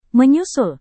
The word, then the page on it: menyusul: Indonesian